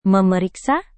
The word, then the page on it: memeriksa: Indonesian